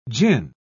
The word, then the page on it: jinn: British English